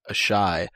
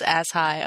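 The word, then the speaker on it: Asshai: Or as some people mispronounce it, a shy. – Yeah. Give it the program, ladies and gents. – It's ass high, obviously.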